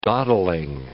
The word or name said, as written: dawdling